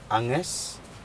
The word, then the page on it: ages: Aneityum